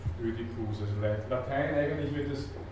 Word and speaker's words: Ödipus: dass man das OI ein bisschen in Richtung Ö ausgesprochen hat, wie auch immer, ähm, Ödipus.